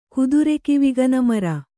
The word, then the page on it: ಕುದುರೆಕಿವಿಗನಮರ: kannada